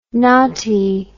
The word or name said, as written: Ngati